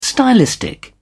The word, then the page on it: stylistic: British English